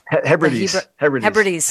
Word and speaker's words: Hebrides: You know, I think it was the Hebrides revival where crime, how do you say that? Am I saying that right? – Hebrides.